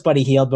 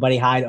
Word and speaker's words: Hield: We know it's Buddy Hield, but we all call him Buddy Hyde over here.